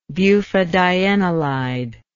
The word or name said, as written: bufadienolide